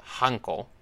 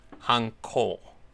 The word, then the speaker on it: Hanko: A domain school, Hanko in Japanese, not to be confused with the seal, uh, Hanko, Hanko, long O is the institution that trained a feudal domain's vassals in scholarly, military, and sometimes also practical arts.